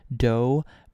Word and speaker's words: Doux: There's a pizza restaurant and there's some dessert shops, and we bought some cookies from this one shop called Dough, but it's spelled like D-O-U-X,